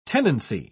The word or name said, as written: tenancy